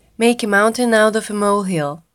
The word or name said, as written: mountain out of molehill